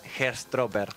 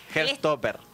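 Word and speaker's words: Heartstopper: Tenés un inglés británico que yo no tengo y a mí me cuesta pronunciar Herstropper. Herstopper.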